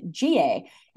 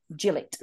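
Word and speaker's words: Gillett: The referee for the game at the weekend, listeners, is going to be 36-year-old Australian-born referee Jared G.A. Is that how you pronounce it? Gillette? G.A.? – Gillette.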